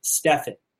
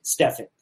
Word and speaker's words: Stefan: Send me a tweet to uh, Project Life Mastery and just use the hashtag Ask Stefan. Okay, Ask Stefan, and make sure that you actually use uh, you pronounce and spell my name correctly.